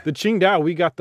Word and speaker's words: Qingdao: Just say, and then it's pronounced Qing-Dao.